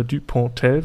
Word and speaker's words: Dupontel: Dann äh, haben wir Vincent Cassel, über den haben wir auch schon mehrfach gesprochen, und Albert Duponel, äh, Dupontel würde ich ihn jetzt mal aussprechen.